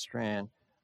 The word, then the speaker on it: Strachan: So this man, Owen Strand, it looks like Strachan, but apparently it's pronounced Strand, he writes Christianity and Wokeness,